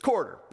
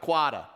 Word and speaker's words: quarter: It's quarter, not quada, all right? That's my Eastern accent for you guys. So that's how it's pronounced, quarter.